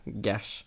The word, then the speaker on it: Gars: G-A-R-S, Gash. – Gash. – Typ eh, som bash, fast Gash.